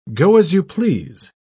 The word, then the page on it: go-as-you-please: British English